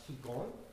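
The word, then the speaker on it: Kegon: And Japanese, the same two characters, flower garland, are pronounced Kigon.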